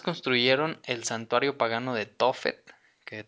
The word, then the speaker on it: Tofet: y por ejemplo encontré en un diccionario Tofet la palabra hebrea, de acuerdo con la pronunciación masorética, resulta de asignarle las consonantes TF, bueno, las vocales que quedan ese sonido Tofet y que se podría traducir en vergüenza, con lo que el término adquiere el significado de escupida o lugar aborrecible.